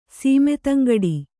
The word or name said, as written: ಸೀಮೆತಂಗಡಿ